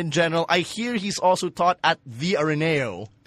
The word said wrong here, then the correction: Ateneo: Well, how do we say it? – Don't insult. Don't insult the school. – No, I'm not insulting it. – Nee, yung pagkakuan, just Ateneo. He's taught at Ateneo.